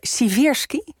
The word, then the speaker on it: Siversky: Die leraar op de middelbare school, meneer Siversky, zeg ik het goed? – Jij zegt goed, ja.